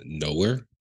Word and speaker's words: Knower: So who I'm adding this week, this is a duo called Nowhere. If anybody knows Nowhere, it consists of Genevieve and Lewis Cole. – Can you spell it? – Yes, Nowhere as in K-N-O-W-E-R.